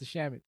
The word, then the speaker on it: Shamet: Mr. Shamet.